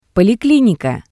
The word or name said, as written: polikinika